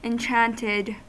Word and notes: enchanted: American English

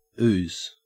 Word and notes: ooze: Australian English

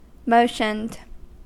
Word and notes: motioned: American English

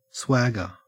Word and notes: swagger: Australian English

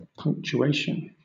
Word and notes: punctuation: British English

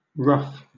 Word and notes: ruff: British English

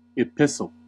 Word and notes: epistle: American English

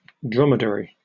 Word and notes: dromedary: British English

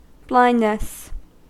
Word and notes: blindness: American English

